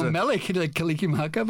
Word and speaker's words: Mele Kalikimaka: Something from Willie Kalikimaka. Did I say that right? – Well, I don't know. I'm not Hawaiian, but, okay, but I know Mele Kalikimaka, but yeah.